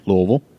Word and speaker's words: Louisville: It's in Louisville. It's September 19th, 20th, and 21st. – He said it right, Louisville.